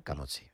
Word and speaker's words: Camozzi: Y por asegurarme, ¿he pronunciado bien tu apellido? – Camozzi, vale. Sí. ¿Con una Z o con dos? – Con dos Z. – Blanca Camozzi. Estupendo.